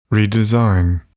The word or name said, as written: redesign